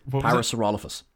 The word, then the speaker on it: Parasaurolophus: A good rule of thumb is the emphasis usually goes on the third syllable of whatever you're doing, of whatever the thing is. So, Parasaurolophus. – Parasaurolophus. That's usually where you break and you put the heavy syllable on.